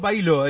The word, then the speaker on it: Bahillo: ¿Está bien pronunciado, Bailo? ¿Es así? Sí, sí, está bien, correcto.